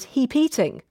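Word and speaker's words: hepeating: Hey, that moment when a man reiterates an idea a woman has previously raised and he gets all the praise for it, it's known as heap-eating, to rhyme with repeating.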